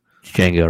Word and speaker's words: Django: Django, right? The D is silent.